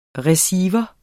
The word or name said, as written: receiver